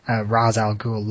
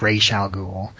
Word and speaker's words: Ra's al Ghul: and they always pronounced his name as Ra's al Ghul instead of Ra's al Ghul, and so I prefer the Ra's al Ghul pronunciation, just because that's what I was introduced to.